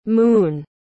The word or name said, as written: mun